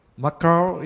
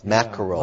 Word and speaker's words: mackerel: Mackerel is... – Yeah, that's okay. It's a difficult word for Japanese to pronounce. Mackerel.